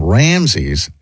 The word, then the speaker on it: Ramses: Ramses. Am I pronouncing that guy's name correctly? – Yeah. – That's how you would say that? Ramses? – Mm-hmm.